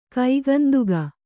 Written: ಕೈಗಂದುಗ

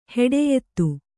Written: ಹೆಡೆಯೆತ್ತು

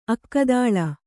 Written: ಅಕ್ಕಿದಳಿ

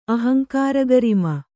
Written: ಅಹಂಕಾರಗರಿಮ

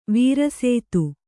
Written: ವಾರಸತಿ

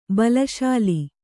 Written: ಬಲಶಾಲಿ

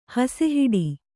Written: ಹಸೆಹಾಡು

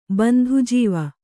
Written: ಬಂಧುಜೀವ

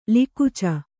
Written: ಲಿಕುಚ

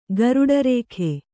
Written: ಗರುಡರೇಖೆ

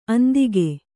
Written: ಅಂದಿಗ

